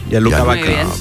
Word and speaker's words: Gianluca Vacchi: Entonces, ¿usted sabe quién es Gianluca Vacchi? ¿Lo he dicho bien? – Sí, Gianluca Vacchi.